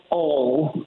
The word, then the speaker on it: Hull: How do you say the name of that place? – It's called Ol. – Ol, excellent. – Not Hull.